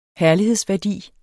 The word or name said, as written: herlighedsværdi